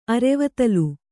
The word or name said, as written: ಅರೆವತ್ತಲು